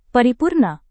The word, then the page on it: paripurna: Indonesian